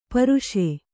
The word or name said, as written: ಪರ್ಷ